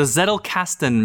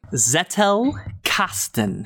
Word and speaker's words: Zettelkasten: The Zettelkasten method. Can you spell it or say it slowly? Zettelkasten.